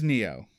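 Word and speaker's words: Nioh: Is it the, I don't know how to say it, Neo? N-I- Yes, that is correct. – Yes! Not the Sekiro Must Die or whatever Shadows say. Yes, okay. – Yeah, it was Neo.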